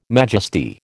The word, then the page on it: majesty: American English